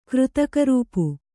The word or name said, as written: ಕೃತಕರೂಪು